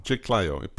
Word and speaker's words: Chiclayo: So you're born and raised in Chiclayo? – Yes. – Did I pronounce that okay? – Chiclayo, Peru, which is the northern part of Peru.